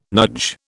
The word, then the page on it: NUJ: British English